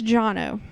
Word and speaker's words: Jono: And I've since learned it's Jono.